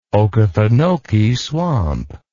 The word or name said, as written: okefenokee swamp